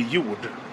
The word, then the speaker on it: Jord: Uh, well, I can I can pronounce it in Swedish. It would be Jord.